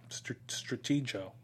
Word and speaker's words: Stratego: Yeah, well, I mean, I've always pronounced Stratego with a soft G at the end, but yeah, it would make sense that it would be a hard G, right? Stratego, great game.